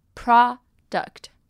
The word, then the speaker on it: product: 那发音比较不一样的呢，则是它的名词产品 product 这边的 o 呢，则是发 r 的音，换成 u 呢是发短音 product。